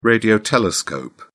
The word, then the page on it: radio telescope: British English